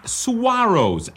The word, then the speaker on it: saguaro: Those are saguaros, S-A-G-U-A-R-O-S, but it's sort of pronounced saguaro.